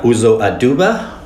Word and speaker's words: Uzo Aduba: And it stars Uzo Aduba, which have been trying to say her name for the past few weeks. I finally got it.